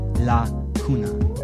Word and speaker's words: lacuna: From Latin, lacuna, an unfilled space or hole, and Spanish, la cuna, cradle, pronounced lacuna.